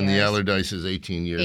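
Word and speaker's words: Allardice: Is that how you pronounce Allardyce? – It? – Allardyce. – All right. Yeah, Allardyce, right.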